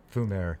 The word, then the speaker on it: fumer: Well, we started with the Tubes, Smoke, La Vie en Fumer, Fumer. That's how they say it.